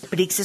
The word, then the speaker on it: Prixz: Y básicamente, Prix